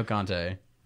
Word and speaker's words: Conte: I was saying C-O-N-T-E. He's linked to PSG right now. Antonio Conte. Wait, what is the genuinely, what is the pronunciation difference? – Conte.